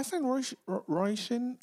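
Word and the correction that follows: Róisín: I can't even say her name. Roisin. – Roisin, Roisin. I found Yeah.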